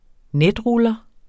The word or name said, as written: netruller